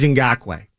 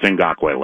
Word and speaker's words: Ngakoue: It is Ngakoue. – Yeah, it's Ngakoue.